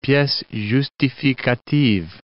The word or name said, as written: piece justificative